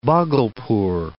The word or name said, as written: Bhagalpur